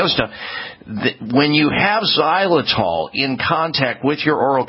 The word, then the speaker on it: xylitol: if they are not rich in xylitol, xylitol is spelled with an X, X-Y-L-I-T-O-L, xylitol,